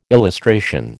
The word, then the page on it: illustration: American English